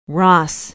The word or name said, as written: Ross